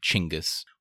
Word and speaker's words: Chinggis: That's how he pronounces it, and how Craig Benjamin recommends you say it, Chinggis.